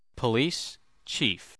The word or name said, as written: police chief